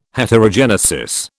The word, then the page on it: Heterogenesis: British English